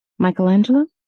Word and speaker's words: Michelangelo: Michelangelo? – Yes, thank you.